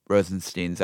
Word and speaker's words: Rosenstein: And that basically then Trump can step in and say, all right, Rosenstein's out. Sorry, that's how you actually pronounce it.